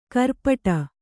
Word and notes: ಖುರಪುಟ: kannada

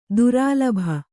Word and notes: ದುರಾಲಭೆ: durālabhe

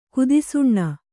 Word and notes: ಕುತ್ಸನ: kutsana

